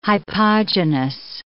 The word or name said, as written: hypogynous